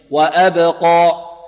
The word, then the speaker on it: وأبقى: انطق معي أقو أقو أب أج أد طيب وأبقى وأبقى القاف مفخمة والألف هذه بمقدار حركتين أبقى أبقى.